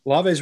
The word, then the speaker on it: Olave: Olave's really good. – I hate how nobody can pronounce his name. – Olave. – Say it then. – No, that's how you pronounce it.